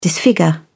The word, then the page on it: disfigure: British English